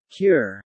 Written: cure

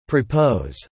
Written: Propose

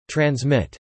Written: transmit

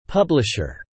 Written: publisher

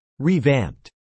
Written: revamped

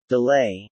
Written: delay

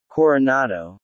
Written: Coronado

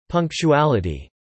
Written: Punctuality

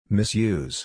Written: misuse

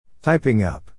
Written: typing up

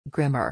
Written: grimmer

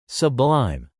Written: sublime

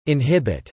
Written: inhibit

